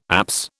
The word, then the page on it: apse: British English